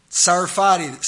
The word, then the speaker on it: Sarfati: A-M-I-R, his first name, Amir Sarfati, silent T, S-A-R-F-A-T-I.